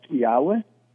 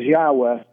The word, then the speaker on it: Yahweh: We know that in the past it was pronounced Yahweh, but the Jews for century, when they would see that word, would pronounce a different word.